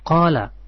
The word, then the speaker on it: qala: Sebetulnya cara membacanya begini, haddatsana fulan, fulan qala, haddatsana qala. Ya, kata-kata qala itu harus dibaca.